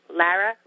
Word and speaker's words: Lara: So my first name, Lara, I always tell people that it rhymes with mascara, and after that they never forget, and I never get called Lara.